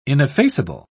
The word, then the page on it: ineffaceable: British English